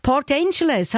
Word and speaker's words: Port Angeles: Die Elisabeth Hegi von Port Angeles, habe ich es richtig gesagt? – Ja, das ist richtig.